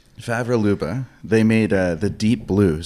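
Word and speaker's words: Favre Leuba: Favre Labua. – Favre Luba? – Yeah, exactly.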